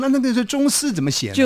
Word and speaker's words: 螽斯: 两只虫并列在冬天的那个冬的两点的那个地方，这个字呢读作中，中国的中，啊，丝呢就是像文哥这么斯文的丝，这这个虫呢叫中丝。